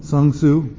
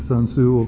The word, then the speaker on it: Sun Tzu: And I've quoted to you this secular guy called Sun Tzu. Okay. It's spelled S-U-N, Sun Tzu, okay.